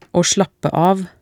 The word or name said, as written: å slappe av